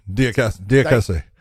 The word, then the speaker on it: Diakiese: Michael Johnson and uh, Mark Tiakesa. – How do you say his last name? Tiakesa. Diakiese. Diakiese. Diakiese.